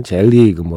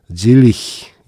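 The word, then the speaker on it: Selig: 그리고 지금 끝난 곡은 노킹 원 헤븐스 도어에서 노킹 원 헤븐스 도어 질리히 예전엔 젤리그 뭐 셀리그 이렇게 불렀는데 독일어 사전에서 검색해 보면 질리히 예 이렇게 비슷하게 발음이 납니다.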